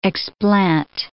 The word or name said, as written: explant